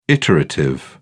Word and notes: iterative: British English

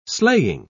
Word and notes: sleighing: British English

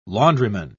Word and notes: laundryman: British English

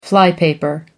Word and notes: flypaper: American English

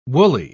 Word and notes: wooly: British English

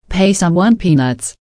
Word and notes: pay someone peanuts: American English

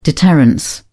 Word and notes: deterrence: British English

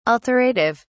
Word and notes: alterative: British English

